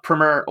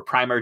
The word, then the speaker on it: primer: Yes, which was about fan fiction and kind of uh, served as a, a, primer or primer, depending on which pronunciation you prefer,